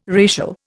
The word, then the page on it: racial: Indian